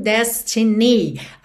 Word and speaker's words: destiny: Normalmente a gente teria destiny. Destiny. A sílaba tônica é a primeira. Mas aqui, por conta da melodia, a gente tem Destiny. Então, ni acabou ficando mais, mais forte, ok?